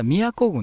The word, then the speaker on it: 京都: 西日本ハムフェアのね、放送の中でね、京都軍と言ったんですけど、これ実は宮古軍なんですよね。はい。非常に、でもね、非常に粋な名前ですよね。 – そうですね。京都と書いて宮古と読む。